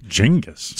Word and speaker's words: Genghis: Genghis. – So there, yeah, that's the correct pronunciation.